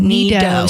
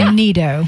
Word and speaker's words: NeeDoh: N-E-E-D-O-H. – Oh, Neato. So kind of like Play-Doh, but right. Neato. – You knead it like dough. Yeah.